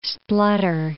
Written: splutter

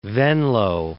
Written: Venloo

